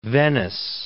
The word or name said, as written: Venice